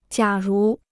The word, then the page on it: 假如: jiǎ rú